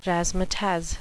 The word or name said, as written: razzmatazz